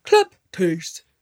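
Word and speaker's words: kleptes (κλέπτης): The Greek word is literally kleptes, or as the guy says it when you have him pronounce it, kleptes.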